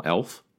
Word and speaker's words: Elf: And Germany perhaps the best because I just like the German pronunciation of National 11 as National Elf.